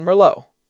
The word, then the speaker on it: Merlot: It is the philosophical equivalent of pronouncing the T in Merlot. Do not do it.